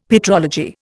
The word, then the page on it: petrology: Indian